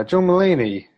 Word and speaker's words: John Mulaney: is it John Mulaney? Is that how you say it? – Yeah, John Mulaney.